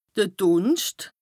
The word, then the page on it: de Dùnscht: Bas-Rhin; Strasbourg